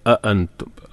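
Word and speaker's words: അഅൻതും (أأنتم): അഅൻതും, അഅൻതും ഹംസ പറഞ്ഞല്ലോ, ശ്രദ്ധിച്ചു ഉച്ചരിക്കണം. മലയാളത്തിൽ നമ്മൾ ആ പറയുന്ന ഉച്ചാരണമല്ല, അ കുറച്ചും കൂടി ഹൽക്കുന്നാണ് ശബ്ദം വരേണ്ടത്. അഅൻതും, അഅൻതും അശദ്ദു ഖൽഖൻ അമിസ് തൻവിന് ശേഷം ഹംസ, പിന്നെ സമാഉ മദ്ദിന് ശേഷം ഹംസ.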